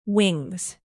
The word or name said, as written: wings